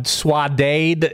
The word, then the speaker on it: saudade: Saudade Stooge. – That's very wrong, but it's fine. – Swadade is my favorite word, not an ad.